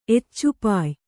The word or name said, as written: ಎಚ್ಚುಪಾಯ್